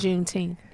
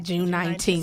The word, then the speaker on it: Juneteenth: But I would just like to say it's pronounced Juneteenth. It's not June 19th.